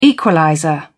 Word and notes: equalizer: British English

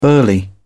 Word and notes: burly: British English

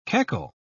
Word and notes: keckle: British English

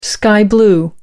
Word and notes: sky-blue: American English

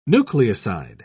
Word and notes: nucleoside: British English

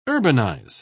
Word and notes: urbanise: British English

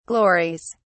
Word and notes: glories: British English

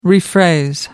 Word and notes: rephrase: American English

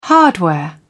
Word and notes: hardware: British English

